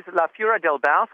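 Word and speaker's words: La Fura dels Baus: La Fura del Baus, is that how it's pronounced? – That is La Fura del Baus.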